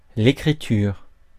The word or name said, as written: écriture